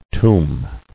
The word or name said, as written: Tombing